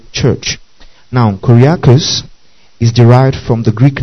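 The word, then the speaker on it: koriakos (κυριακός): Now, the English word church is derived from the Greek adjective koriakos.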